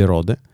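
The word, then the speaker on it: Røde: Quindi secondo me è tutta una farsa e si legge Rode, che è una...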